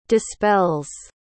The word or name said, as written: dispells